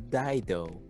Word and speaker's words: Dido: o en inglés bien pronunciado sería Dido, que se ah, llama...